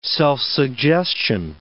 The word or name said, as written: self-suggestion